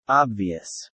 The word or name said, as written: obvious